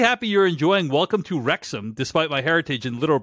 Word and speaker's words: Wrexham: The W in Wrexham is silent. It's just Wrexham, which is only funnier as they say Wrexham no less than 15 times in the first episode alone.